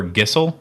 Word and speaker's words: Geisel: Did you say his name was Theodore Gissel? – Yeah. – Is that how you actually pronounce it? – That's how I've always said it.